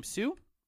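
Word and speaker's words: Hsu: We have both the Everything Everywhere All at Once actresses, Jamie Lee Curtis, Stephanie, I believe you pronounce her last name, Sue.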